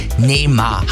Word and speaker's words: Neymar: Da sind ja jetzt heute Abend alle Blicke auf einen gerichtet, nämlich Superstar Neymar.